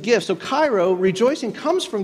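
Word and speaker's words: Cairo (χαίρω): It's this word, it's actually pronounced Cairo, kind of like the city.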